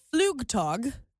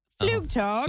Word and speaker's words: Flugtag: It's pronounced Flugtag. Flugtag. – Flugtag, okay. – Means flying day in German.